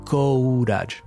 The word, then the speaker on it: courage: It's spelled C O U R A G E, and the common mistake is saying courage instead of courage. You have to fight the temptation to close your mouth and pronounce the O and the U separately. We don't say courage, we say courage, courage.